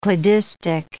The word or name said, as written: cladistic